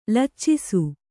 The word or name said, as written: ಲಚ್ಚಿಸು